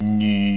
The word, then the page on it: nü: nu5